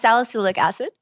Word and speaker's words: salicylic acid: Salicylic acid.